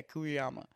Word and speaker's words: Kuriyama: Name is Chiaki Kurayama? Kuriyama? Yeah, Kuriyama.